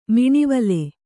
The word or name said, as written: ಮಿಣಿವಲೆ